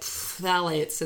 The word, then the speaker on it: phthalates: But really the main culprits that you want to avoid include phthalates, that's spelled phthalates. It sounds like phthalates. It's like a really weird spelling.